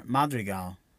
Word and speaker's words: Madrigal: Yeah, the way you said it right there, Madrigal. That's good. Mirabel.